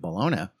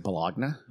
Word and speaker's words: Bologna: So Volta was at University of Pavia, and Galvani was at University of Bologna. And that's how you say it. – Is it Bologna? – Bologna. – Bologna. Sorry. God. – I'm saving you from the Italian wrath, okay?